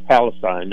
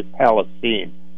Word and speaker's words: Palestine: Well, you know, when you look just in the last couple of days, here is this terrible tragedy out there, and I believe I've learned to pronounce it correctly, it's East Palestine. You know, my instinct was Palestine. No, no, no, no. You say Palestine.